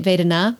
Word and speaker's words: Vedana: And I'm saying it Vedana because it's a long A.